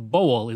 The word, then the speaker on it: Bowell: Also, I've heard his name pronounced Bowell and Bowell, but I believe Bowell is the correct pronunciation, and that's the one I'm going to use.